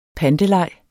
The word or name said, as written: panteleg